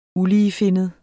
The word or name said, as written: uligefinnet